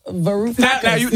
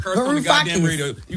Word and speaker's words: Varoufakis: Varoufakis. – Now you're on stage. Now they mad because you said right. – their name wrong. – Varoufakis.